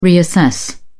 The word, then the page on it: reassess: British English